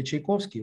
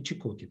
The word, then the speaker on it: Чайковський: Е, і тут я розумію, що іспанцям важко вимовити Чайковський, вони е, так вимовляють е, слово Чайковський, Чікокі.